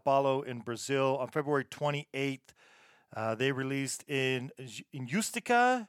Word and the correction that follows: Injustiça: Let's see if I'm saying that right. – Injustiça. – I didn't say it right.